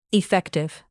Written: effective

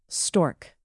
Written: stork

